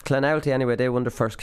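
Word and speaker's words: Clonoulty: So I messed up. I was Clanulty, but it's Clanowlty. So they're actually breaking it down into three different syllables for me to actually be able to pronounce this. So it was Clan, Owlty, Clanowlty.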